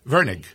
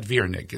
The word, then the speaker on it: Virnig: Virnig. Yep. – Not Virnig. It's Virnig. Okay. Yep. It's the normal pronunciation.